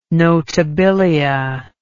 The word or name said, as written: notabilia